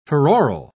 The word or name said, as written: peroral